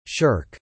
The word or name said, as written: shirk